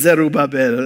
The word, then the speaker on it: Zerubbabel: So anyway, but it's Zerubbabel. That's how it's pronounced, just so you know.